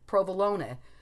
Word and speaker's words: provolone: You're supposed to pronounce the E or the E, provolone, calzone.